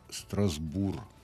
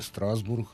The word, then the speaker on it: Страсбург: Я звертаю увагу і привертаю увагу слухачів до того, що Мортен Енберг вимовив назву столиці Ельзасу-Лотарінгії Страсбург. Так, як, власне, вона і лунає французькою, а також ельзаською мовою, а не Страсбург, як ми звикли говорити.